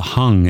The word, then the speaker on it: Hang: The London group also uses the Hung. It's spelled hang, but it's pronounced hung,